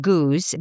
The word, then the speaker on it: Goudge: In Britain, actually, her name is pronounced Goose, but we British and Americans don't always agree on pronunciations. So her name is spelled G-O-U-D-G-E. So I pronounce it the way it's spelled, but I believe it should be pronounced Goose.